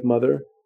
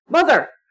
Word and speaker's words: Mother!: You didn't say it right. You have to pronounce it. Mother! There's an exclamation point at the end of it.